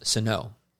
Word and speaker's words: Sinnoh: Yeah, that's why I know it's pronounced Sinnoh. – Right. – Mm. Y'all are saying it wrong.